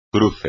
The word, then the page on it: cruce: espagnol